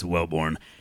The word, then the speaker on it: Welborn: It's Welborn. Thomas literally got it wrong on air yesterday when I was producing. He said Wilborn, and I had to correct him. – Wilborn. It's like it's spelled W H and two E's.